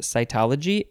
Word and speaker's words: cytology: Is it pronounced cytology? Yes, it is.